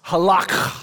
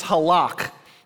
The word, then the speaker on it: halak: Now, this is a very specific Hebrew word that's pronounced halak. Because when in Hebrew, you got to bring it deep from like within your throat. It's like, ugh. So it's halak.